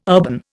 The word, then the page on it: urban: Indian